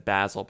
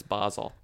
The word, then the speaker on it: Basel: No, I'm saying I said Basel, but it's Basel. – Okay, thank you. – I was correcting my pronunciation.